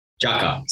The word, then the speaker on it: Tjaka: So you guys first started, is it Tjaka? – Tjaka, so silent T.